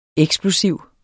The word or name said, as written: eksplosiv